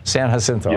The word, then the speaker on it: San Jacinto: San, is it, how do you say that? Jacinto? – San Jacinto. – Jacinto. – California. – San Jacinto. – Yes.